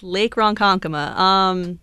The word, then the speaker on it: Ronkonkoma: Yes, Lake Ronkonkoma. You did pronounce it correctly.